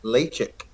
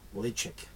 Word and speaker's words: Ledaig: So it's spelled L-E-D-A-I-G. Now, most people would think that's Ledaig. But it's actually pronounced Lechick. – Yes, Lechick. – Lechick or Lechick.